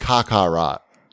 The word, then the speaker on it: Kakarot: Carrot. No, it's pronounced Kakarot. – Oh. – As in a duty that's been sitting around too long.